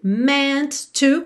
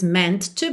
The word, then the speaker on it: meant to: E meant to, como eu até já falei hoje lá na parte 2, você pronuncia um T só, então não diz meant to be, e sim meant to be, ok?